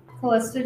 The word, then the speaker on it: Calista: Also, check out our personal Instagrams, Allie.joy173 and Calista J1776.